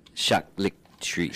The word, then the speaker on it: charcuterie: This is how it's pronounced. – Charcuterie. – Charcuterie. Yeah, okay.